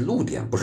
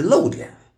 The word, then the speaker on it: 露点: 露点就是那个暴露的露啊，多音字。露点啊是露点，不是漏点。